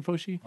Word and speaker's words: Fauci: And from what I know after the fact, I think even Fauci, is that how you say his name? Anthony Fauci? – Yeah.